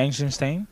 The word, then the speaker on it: Eisenstein: Passo a un clàssic entre clàssics de Sergei Eisenstein, o com es pronunciï. – Sí, sí, Sergei Eisenstein.